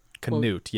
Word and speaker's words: Canute: Is that what you how you'd say it? – Canute, yes, that's how you pronounce it.